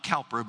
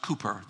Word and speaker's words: Cowper: The author is a man named William, not Cowper, but pronounced Cooper, the English pronunciation, who was a manic depressive, a man who was plagued by bipolar mental illness.